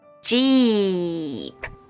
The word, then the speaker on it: 鸡: Let's say, 鸡鸡鸡 An easy way to pronounce it is to think of the English word, jeep. Jeep.Just make the J sound and then plong it.